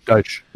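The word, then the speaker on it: Deitsch: Richard Deitch. Mm-hmm. I think I'm pronouncing that correctly. If I'm not, oh well. – Deitch, it is.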